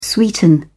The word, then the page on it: sweeten: British English